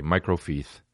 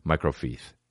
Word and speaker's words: microfiche: And he was an older guy, and he was talking about microfiche. And every time he would say microfiche, he'd say microfiche. And it's C-H-E. Not anything but C-H-E.